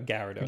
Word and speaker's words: Gyarados: Is that how you pronounce it? – No, Gyarados. Some people say Gyarados.